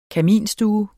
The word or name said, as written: kaminstue